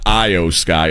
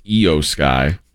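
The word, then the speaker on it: Iyo Sky: It's not Io Sky. It's Io Sky, according to Estrada.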